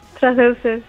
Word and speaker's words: traceuses: ¿Lo he pronunciado bien? ¿Es así como se os llama a las mujeres que practicáis el parkour, troces. – Traceuses, sí,